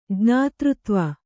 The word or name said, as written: ಜ್ಞಾತೃತ್ವ